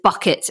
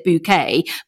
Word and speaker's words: Bucket: It's kind of like the hyacinth bucket of Brexit. You know, she pronounces it bouquet, but it's still bucket.